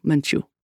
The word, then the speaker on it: Menchu: I am Father Arturo Menchu, accent on the second syllable.